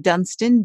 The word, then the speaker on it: Dunston: k-y-r-i-n and then the last name dunston d-u-n-s-t-o-n-m-d dot com um,